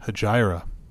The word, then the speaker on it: Hejira: I would highly recommend to anybody who's a fan of music, listen to Hejira by Joni Mitchell. It's a great album. – Hegira.